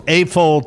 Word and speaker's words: AFOL: And he has an official AFOL title. I hope I'm pronouncing that right. – Yes.